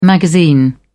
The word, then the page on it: magazine: British English